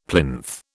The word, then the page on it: plinth: British English